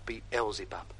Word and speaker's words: Beelzebub: So help me, Beelzebub. – That's pronounced Beelzebub.